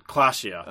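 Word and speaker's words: Klaasje: How do you pronounce her name in Disco Elysium? Classe? – Clashia.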